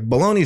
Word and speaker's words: bologna: Like, bologna shouldn't rhyme with pony, but it does. – Right. – But it's pronounced bologna.